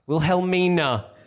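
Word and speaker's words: Wilhelmina: Will Helmia. – Will Helmina. Why can you never see the N in that word? – Because I have issues.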